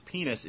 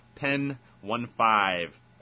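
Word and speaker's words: Pen15: Apparently the appropriate pronunciation of the group which we've been referring to as Penis is Pen 15.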